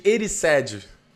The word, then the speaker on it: Erised: Ozeged... Ozeged... É, Ozeged... Falei errado. Espelho de Ozeged... O, o, caralho! Espelho de Erised.